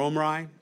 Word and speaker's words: Omri: There's a man by the name of King Omri. Again, at that time, or Omri, some people like to pronounce it.